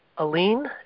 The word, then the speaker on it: Allene: Is it Aline? A-L-L-E-N-E.